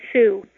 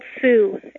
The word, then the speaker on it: Sue: My mom and dad named me Sue, just plain old Sue, S-U-E, which I always thought was the most boring name in the world.